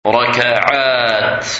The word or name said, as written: Rakaat